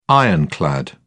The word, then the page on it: ironclad: British English